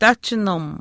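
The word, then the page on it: taahchinim: Chisasibi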